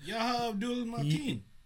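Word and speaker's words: Yahya Abdul-Mateen: Yaha Abdul Mateen.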